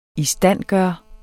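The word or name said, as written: ISTANDGØRE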